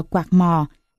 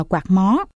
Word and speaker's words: quạt mò: Phú ông gọi đó là quạt mò, có nơi đọc là quạt mó.